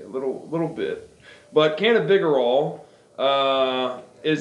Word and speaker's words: cannabigerol: CBG, funny you should ask, is actually cannabigerol, or cannabidrol as some people pronounce it.